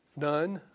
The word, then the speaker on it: Nun: You pronounce it Nun, I guess. N-U-N.